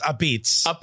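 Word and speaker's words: apizza: It's pronounced a pizza. – I'm ready. – Yeah, no, they have an A at the end. You're not supposed to pronounce it.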